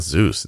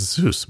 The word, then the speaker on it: Zeus: Is it Zeus? It's not Zeus. It's Zeus. Wow.